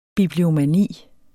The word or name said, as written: bibliomani